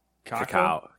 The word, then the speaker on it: cacao: How do you say, is it, it's not cocoa, it's cacao.